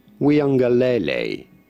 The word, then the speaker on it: Uiagalelei: Here we go. – DJ Uiagalelei. – Uiagalelei. – Uiagalelei. – Lelei. DJ Uiagalelei.